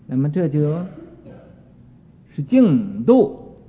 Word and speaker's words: 净土: 这个本来是个土字，但是啊，在这个佛经上应该读成一个度字啊，净度。有的没有读过书的人啊，没有什么学问的人啊，就说净土法门呢，净土法门啊，这不是净土法门，是净度法门，国度啊。这个字应该读成这个去声。